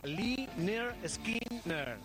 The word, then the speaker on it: Lynyrd Skynyrd: El disco se titulaba Pronunciad Lynyrd Skynyrd. Somos los Lynyrd Skynyrd. ¿Por qué somos los Lynyrd Skynyrd? Mira, existía un profesor de gimnasia que se llamaba Leonard Skinner.